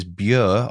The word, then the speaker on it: Bure: I said River Bure when the correct pronunciation is Bure or Bure, depending on your accent.